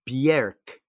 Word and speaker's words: Björk: Her name is pronounced Björk. when you see the little umlauts on top.